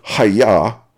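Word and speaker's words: ha-yah (היה): In both cases, the original Hebrew word being translated is, quote, hayah, unquote. This Hebrew word is pronounced with a hard H sound, as in ha-yah.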